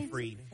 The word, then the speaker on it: Seyfried: Is that how you say her name? – Seyfried.